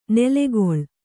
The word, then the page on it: ನೆಲೆಕೊಳ್: nelekoḷ